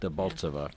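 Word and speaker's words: Debaltseve: a rail junction called Dolbatsev, if I'm pronouncing Debaltseva. – It. Debaltseva. Yeah.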